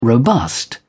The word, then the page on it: robust: British English